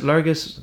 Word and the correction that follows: Lurgashall: Lurgashall. Lurgashall, right. I, I, was, I looked at Lurgus Hall.